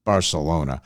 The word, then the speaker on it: Barcelona: A single for them so far, Barcelona, which of course is the Catalan pronunciation for Barcelona.